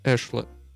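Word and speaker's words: éclat: One of the first things I wanted to notice here is her use of the word eschlet, that is spelled E-C-L-A-T, and the E has a little accent mark above it.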